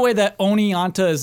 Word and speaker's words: Oneonta: Onianta, O N E O N T A, Onianta, New York.